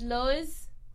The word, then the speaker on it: Lowe's: So I don't know why I read it as Lowe's.